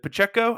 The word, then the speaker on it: Pacheco: Pacheco's good. Pacheco? I don't know how to pronounce that name. – It's Pacheco, yeah.